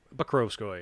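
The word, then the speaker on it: Pokrovskoye: Rasputin was born in Pokrovskoy. We're going with that? – Pokrovskoy.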